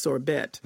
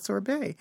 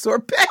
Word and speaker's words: sorbet: So the guy's describing their lunch, and it starts off with they're ordering sorbet, in which Don Lemon tells the interviewer, you know, it's pronounced sorbet. And the guy starts questioning himself, thinking, gee, all these years I thought it was sorbet.